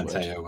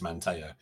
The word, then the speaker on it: Manteo: I forget the details now, but the correct pronunciation is Manteo.